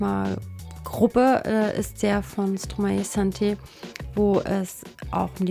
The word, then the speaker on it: Stromae: Wie spricht man den eigentlich aus? – Stromae. – Stromae. Stromae. Okay.